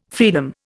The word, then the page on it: fraenum: Indian